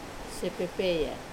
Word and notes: pepeya: wamesa

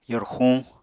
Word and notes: yorxuñ: apatani

